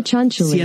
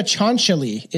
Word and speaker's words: Cianciulli: I'm looking up the pronunciation. C-I-A-N-C-I. Sinciulli. – Leonardo Cianciulli. – Cianciulli? – It sounds like Leonardo Cianciulli. – Leonardo Cianciulli is supposed to be how it's pronounced.